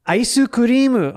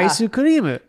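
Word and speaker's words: Aisu kurimu (アイスクリーム): Aisu kurimu onegaishimasu. Aisu kurimu is very much English, but you have to say it like a Japanese person would. – Yeah. – Aisu kurimu.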